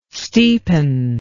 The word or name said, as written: steepen